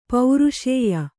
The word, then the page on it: ಪೌರುಷೇಯ: pauruṣēya